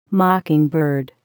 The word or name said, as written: mockingbird